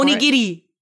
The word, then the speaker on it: onigiri: Go for onigiri.